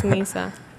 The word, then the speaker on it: Nizza: But I know in Spanish it's Nizza. – Nizza. Oh, N-I-Z-Z-A. Right. So exactly like pizza, but with an N.